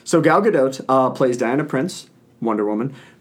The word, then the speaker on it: Gal Gadot: Basically, the movie is about Diana Prince, played by Gal Gadot, which I have come to the conclusion that that is how you pronounce her name. – Because that's how she pronounces it.